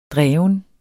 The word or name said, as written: dreven